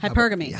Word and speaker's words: hypergamy: He said hyperopigamy. I believe I said that right. – Hypergamy. – Hypergamy. Yep. Excuse me.